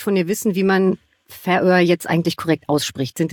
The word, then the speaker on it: Färöer: Die korrekte Aussprache ist die Färöer. Betonung auf der ersten Silbe.